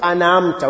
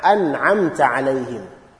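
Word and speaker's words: أَنْعَمْتَ: When you say أَنَعَمْتَ, it's more like you are flowing. أَنَعَمْتَ. And that is wrong. So is an'a, an'a, an'amta. And also ayn, the pronunciation of ayn must be very clear. It's not an'amta, an'amta. It's not an'amta. It's an'a, an'amta alayhim.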